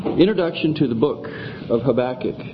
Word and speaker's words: Habakkuk: Some do pronounce it that way, Habakkuk. To me, I just can't quite live with that, so I prefer Habakkuk. I don't know that I've ever looked it up in a dictionary for the preferred pronunciation, but Habakkuk is good enough for me. The diacritical marks, Yeah, accent on the second syllable instead of the first.